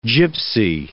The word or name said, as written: Gypsy